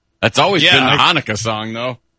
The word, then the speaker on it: Hanukkah: I don't know how do you say it? – The Hanukkah song? – Oh, is that what it is? – Oh, yeah.